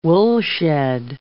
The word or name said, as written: woolshed